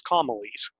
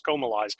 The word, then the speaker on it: Comalies: Of course, if you look at it and you're American, you think it's Comalies, but apparently it's pronounced Comalies.